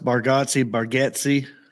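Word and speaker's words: Bargatze: And I just really wanted that to happen because two buildings down from me, Nate, and I always say his last name wrong, Bagazzi. I have like a weird, not a phobia of saying names. Like I get stressed when I have to say a name that's just not easy. – Yeah. I've heard it pronounced Bargazzi.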